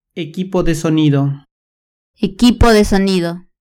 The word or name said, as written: equipo de sonido